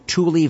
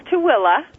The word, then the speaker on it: Tooele: But tonight we're going to focus just, am I pronouncing it correctly? Tule Valley? – It's Tooele. – Tooele. – Everybody pronounce it Tule. That's what it's spelled.